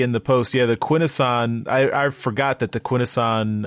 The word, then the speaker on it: Quintesson: I am not. I am pronouncing it correctly. Quintesson. That's how you say it.